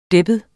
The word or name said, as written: deppet